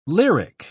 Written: lyric

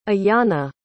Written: Iyanna

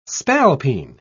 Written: spalpeen

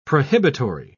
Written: prohibitory

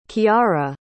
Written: chiara

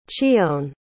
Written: chione

